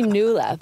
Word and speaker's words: Nuala: It's actually Nuala, but everyone calls it Nuala because the way that it's spelled. And right. It's an Irish name, actually, and it's a nickname.